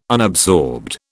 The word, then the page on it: unabsorbed: British English